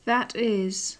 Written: that is

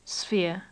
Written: sphere

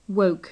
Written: woke

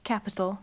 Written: capital

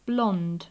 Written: blond

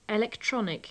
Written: electronic

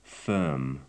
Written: firm

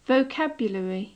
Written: vocabulary

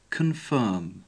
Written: confirm

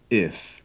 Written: if

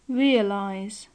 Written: realize

